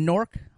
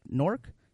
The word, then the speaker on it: Newark: We're from Newark. I'm like, Newark? Yeah, N-E-W-A-R. Oh, you mean Newark? Oh, we don't pronounce it like that.